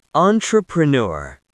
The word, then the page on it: entrepreneur: American English